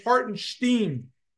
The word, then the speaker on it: Hartenstein: He referred to him as some guy named Hartenstein, and he said he didn't even pronounce his name right. It's Hartenstein, Stephen A. Smith. Say his name right.